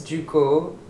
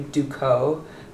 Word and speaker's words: Ducos: So yeah, it's kind of weird, but technically it's Ducos. In English, to say it like French would be Ducos, But everybody calls me Ducos.